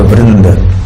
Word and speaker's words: Vrinda: Vrinda means a group, as in Gaura Bhakta Vrinda, you know, the group of devotees of Gauranga Mahaprabhu. So there is Vrinda, because it's feminine, so it's a long A at the end.